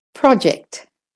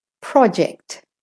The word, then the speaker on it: project: The first word is project. Project. Project. And if you're practicing the American accent, it's project.